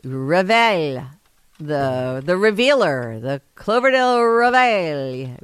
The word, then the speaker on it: Reveille: Huh, but the pronunciation that I looked up says just pronounce it like this. – Reveille.